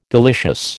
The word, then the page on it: delicious: American English